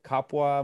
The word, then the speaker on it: kapwa: To end with, you talk about the Tagalog idea of kapwa. Am I pronouncing that correctly? – Yeah.